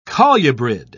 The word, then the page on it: colubrid: British English